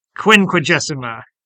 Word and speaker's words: Quinquagesima: Quinquagesima, I don't know if I remember how to say that correctly, but the Sunday before Lent. – Quinquagesima.